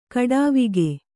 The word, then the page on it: ಖಡಾವಿಗೆ: kannada